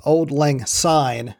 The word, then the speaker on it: Auld Lang Syne: The Scottish pronunciation of that phrase is Auld Lang Syne, not a Z the way we would say it.